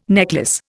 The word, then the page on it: necklace: Indian